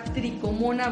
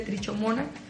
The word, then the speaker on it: trichomona: Que es un parásito que se llama tricomona vaginalis se escribe trichomona. – Es también correcto decirle trichomona.